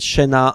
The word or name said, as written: Chenas